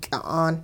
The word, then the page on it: ky'a'aan: hupa